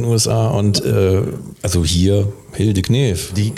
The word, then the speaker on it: Knef: Ich glaube, du darfst sie auch Knef nennen. – Ja, aber das K spricht man ja nicht. – Ja, das ist ja eine Deutsche.